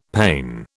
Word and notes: pane: British English

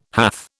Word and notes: hath: British English

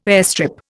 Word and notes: airstrip: Indian

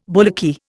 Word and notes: bullocky: Indian

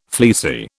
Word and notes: fleecy: British English

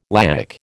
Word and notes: laic: American English

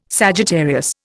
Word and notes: Sagittarius: Indian

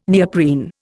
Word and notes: neoprene: Indian

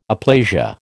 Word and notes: aplasia: American English

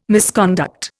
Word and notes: misconduct: Indian

